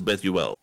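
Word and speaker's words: Bezalel: It's spelled B-E-Z-L-E-L. Pronounced it, I looked up, says Bethuel.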